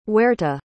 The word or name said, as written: huerta